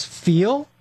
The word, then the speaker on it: Feal: You are a CEO of a suspension company, and the name is Feel. Is that correct? – Yeah, it's pronounced feel suspension, and it is spelled F-E-A-L, suspension.